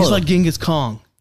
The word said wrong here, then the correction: Khan: Or it's Khan, I'm sorry. Khan. I keep saying that wrong.